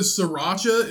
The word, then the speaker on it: sriracha: Bro, did you know that sriracha is spelled S R I R A C H A? So it's sriracha. Are you fucking kidding me? right here, sriracha.